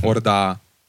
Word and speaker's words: Horda: H spricht man im Französischen nicht aus. Orda.